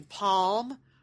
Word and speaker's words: pajamas: How do you pronounce the second syllable of P-A-G-A-M-A-S? – Pajamas. Pajamas. – So your choices are with the vowel in jam or with the vowel in palm, or other. So palm.